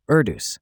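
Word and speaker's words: Erdős: And not just any conjecture, the Erdos unit distance conjecture. Did I say that right? – Nailed it. Erdos, as in Paul Erdos, the legendary mathematician who basically collected unsolved math problems like Pokemon cards.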